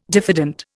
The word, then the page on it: diffident: Indian